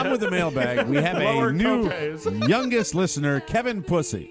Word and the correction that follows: Pusey: Kevin Pusey, like Gary Busey.